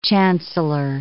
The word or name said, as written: chancellor